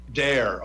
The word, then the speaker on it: Doerr: it's pronounced Dare. – Dare. Okay, I'm going to say it then correctly.